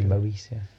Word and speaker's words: Moesia: When we first started this podcast, I thought it was Moesia, and you just pronounce it in a really dodgy way. – Oh. – It's actually Moesia.